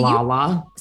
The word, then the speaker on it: Lala: I've listened to Layla's podcast. I've loved Layla on your show. I mean, to some degree, Lala.